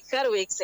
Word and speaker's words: Harwicz: la biografía de Ariana Hardwicks. Hola. ¿Pronuncié bien el apellido? Porque es medio difícil para mí. – Hola. Sí, Hardwicks, está bien.